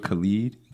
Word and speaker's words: Khalid: The joint with Khalid. That's how I say, that's how you say it, right? – Yeah.